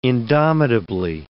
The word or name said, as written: indomitably